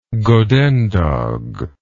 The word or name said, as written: godendag